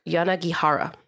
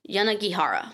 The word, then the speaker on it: Yanagihara: Yanagihara. – That sounds right to me. – You pronounce it on all the vowels. – Yanagihara.